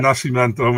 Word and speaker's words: Nascimento: We just go with Nascimento, man.